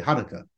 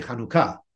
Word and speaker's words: Hanukkah: Generally, in the Ashkenazi custom, as is typical in the United States, people say Hanukkah. But in the Sephardic custom, you say Hanukkah with the accent on the end.